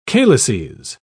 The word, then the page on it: calyces: British English